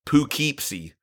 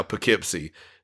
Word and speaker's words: Poughkeepsie: And this one brought something really unique because it's a it's live in Poughkeepsie, which I never in my life thought I would say that right, because I always thought it was like Poughkeepsie.